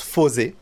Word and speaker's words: Fourze: Ça s'écrit Fourze. Ça se prononce Fosé.